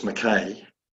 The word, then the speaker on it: Mackay: First of all, I probably should say it's Mackay. – Oh, what have I been saying? Oh, God, that's terrible. – Rhymes with make hay.